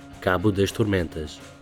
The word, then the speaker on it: Cabo das Tormentas: Anyway, we did find a Dutchman who grew up in Portugal, so here's everything we mispronounced last episode. Mispronounced just a little bit better. – Bartolomeu Dias, Vasco da Gama, Volto do Mar, Cabo das Tormentas, Cabo da Boa Esperança.